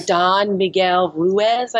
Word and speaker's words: Don Miguel Ruiz: By Don Miguel Ruiz, I believe that's how you say his name.